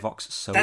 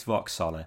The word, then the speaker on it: Vox Sola: So, Chris, Vox Sola. Am I pronouncing that correct? Was it Vox Sola? – That's how I've pronounced it too. So yeah. I've called it that for 19 years. I can't change now. It's Vox Sola.